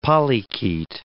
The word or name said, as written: polychaete